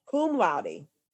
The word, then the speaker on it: cum laude: Cum laude.